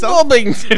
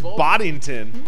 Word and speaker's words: Boddington: How do you say that word? – It's Bobbington. – It sounds like you say Bobbington. – What a... – It's Boddington. – Boddington.